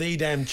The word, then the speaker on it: Edam: Well, look, we're not Dutch, Dino, but I'm going to go with Edam cheese. – Me too, I'm going with Edam cheese. – So there we are. Take that as the pronunciation.